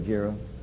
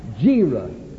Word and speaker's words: Gera: You notice I'm pronouncing that carefully, Gera. Gera.